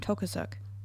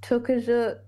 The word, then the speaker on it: Tokarczuk: I feel like Olga, how do you say her last name? Tokarczuk. – Yeah, I think it's Tokarczuk. – Tokarczuk.